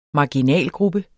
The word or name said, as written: marginalgruppe